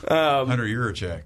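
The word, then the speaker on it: Hunter Yurachek: And for the record, that is the first time I have been able to say his name the right way. – Hunter Yurecek.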